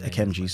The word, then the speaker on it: Akemnji: It's just AKEMNJI. So if you want to add me on that, do so. – Spell it. – AKEMNJI.